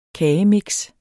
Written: kagemix

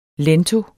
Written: lento

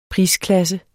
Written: prisklasse

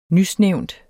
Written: nysnævnt

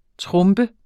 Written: trombe

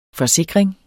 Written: FORSIKRINGENS

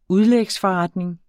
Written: udlægsforretning

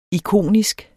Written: ikonisk

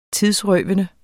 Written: tidsrøvende

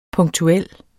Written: punktuel

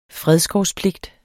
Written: fredskovspligt

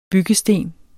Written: byggesten

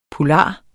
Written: poulard

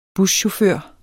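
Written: buschauffør

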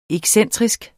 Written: excentrisk

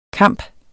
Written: kamp

